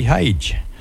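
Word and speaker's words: Haug: All right, track coming up now from Heidi Hodge. Heidi Hage. H-A-U-G, I think it's how she spells it, pronounces it.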